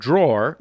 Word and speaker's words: drawer: Zach asks My girlfriend was raised by her mother to pronounce the word drawer like draw. However, I tell her this is incorrect and it should be pronounced with the er at the end, as it is spelled drawer.